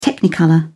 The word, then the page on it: technicolor: British English